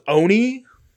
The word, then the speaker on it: Olney: But it's like spelled dumb, O-L-N-E-Y, but it's pronounced Oni, like the animator.